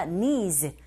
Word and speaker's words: needs: needs가 아니라 needs. 바로 z로 가시면 되는데, 강세 e 이 부분에 주시고요.